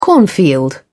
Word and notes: cornfield: British English

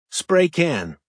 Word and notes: spray can: American English

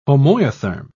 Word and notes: homoiotherm: British English

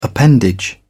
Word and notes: appendage: British English

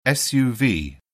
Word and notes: suv: British English

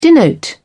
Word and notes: denote: British English

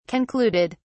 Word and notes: concluded: British English

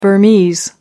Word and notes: burmese: American English

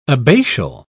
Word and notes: abbatial: British English

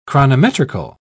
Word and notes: chronometrical: British English

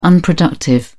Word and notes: unproductive: British English

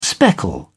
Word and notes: speckle: British English

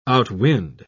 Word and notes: outwind: British English

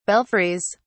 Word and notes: belfries: British English

